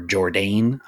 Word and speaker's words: Jourdain: Actually, I asked how to pronounce this, cause it's spelled J O U R D A I N. So would that be like Jordan or just, or Jourdain. I don't know. – Yeah, Jourdain. – Jourdain. Okay. Jourdain.